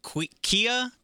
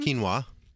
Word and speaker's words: Quia: This is from their Quia line. – Quinoa. – No. Quia. – Okay. We haven't done that. – Cocoa Coconut Super Flakes. – Okay. – Yay. It's pronounced Quia.